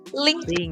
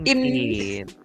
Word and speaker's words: LinkedIn: Bacanya LinkedIn. LinkedIn.